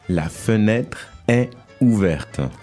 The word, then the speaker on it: est: Is is est. – Est. – Which is here E-S-T, mm-hmm. – But you pronounce est.